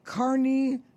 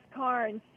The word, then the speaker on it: Carne: And my goodness, you are now the first female international president of, now I can't even begin, Kearney Golf Links. – It's pronounced Karn, C-A-R-N-E.